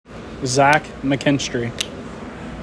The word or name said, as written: Zach McKinstry